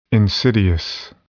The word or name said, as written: insidious